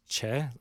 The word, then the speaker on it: Choi: So Kim Tan's brother, Kim Won, okay, his real name is Choi Jin-hyuk, but the way we read it is Choi, C-H-O-I, but it's Che, as in C-H-A-E, right?